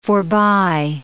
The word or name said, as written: forbye